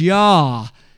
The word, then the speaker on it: Yah: And in that regard, and in that great statement of song of praise, they pronounced God as Yah and praised Him in that way.